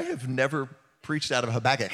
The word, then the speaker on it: Habakkuk: The prophet Habakkuk in chapter 3, verse 17, uh, the uh, the cat pronunciation of that is Habakkuk. Habakkuk.